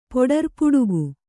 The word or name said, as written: ಪೊಡರ್ಪುಡುಗು